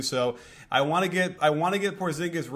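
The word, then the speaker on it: Porziņģis: They were telling me that the correct pronunciation is Porzingis.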